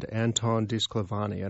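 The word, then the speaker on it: Anton DiSclafani: It's a debut novel called The Yonalossi Writing Camp for Girls by a woman named Anton DiSclavani. And I hope I'm pronouncing all of those things right. – You did that so well.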